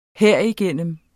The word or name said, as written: herigennem